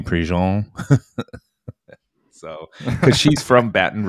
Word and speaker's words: Prejean: First off, I had no idea it was Prejean. I've been pronouncing it Prejean for years. Like rhymes with Dijon.